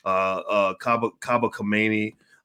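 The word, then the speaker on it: Kaba Kamene: Dr. Ken Harris, Scheherazade Ali, Riza Islam, Kaba Kabane, did I say that correct? I want to make sure I say it right. Yes.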